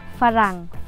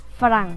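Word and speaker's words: farang (ฝรั่ง): frang farang farang nhưng mình đọc nhanh là farang farang farang farang nó giống như tiếng việt mình luôn á đọc phát âm như tiếng việt nhưng trong không phải là từ,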